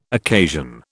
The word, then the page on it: occasion: British English